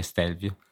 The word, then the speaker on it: Stelvio: Sì, no, eh, comunque la pronuncia è Stelvio.